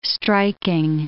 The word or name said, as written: striking